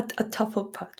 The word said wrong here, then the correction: Hufflepuff: How do you say that? Say that again. – Hufflepuff. – Hufflepuff. Oh my goodness, that didn't sound at all like Hufflepuff.